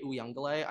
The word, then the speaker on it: Uiagalelei: The Clemson, and also like I was getting roasted for how I pronounce DJ Uiagalelei. I'm pretty sure it's DJ Uiagalelei. I think I said Uiagalelei.